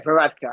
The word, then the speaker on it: Varadkar: Now, the Prime Minister, Leo Varadkar, is it? Yeah, Varadkar.